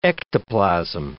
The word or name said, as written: ectoplasm